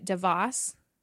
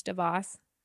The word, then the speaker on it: Davos: So this week is the World Economic Forum annual meeting, also known as so I think Americans say it Davos or Davos, but it's actually pronounced Davos.